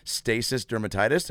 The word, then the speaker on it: stasis dermatitis: Dr. Lee must make a special house call to see a woman with stasis dermatitis.